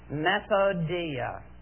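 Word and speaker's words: methodeia: It's a Greek word, and as I pronounce it, see if you recognize the English word that has been drawn from this Greek word. Methodia. Method. methodical.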